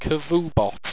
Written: cavubot

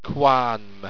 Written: quarnmn

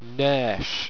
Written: nairsh

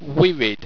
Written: wirid

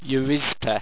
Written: yerizta

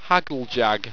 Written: hagljag

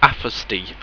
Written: afastey

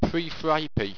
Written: prefripy

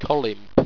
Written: colimp

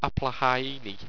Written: applahaiiny

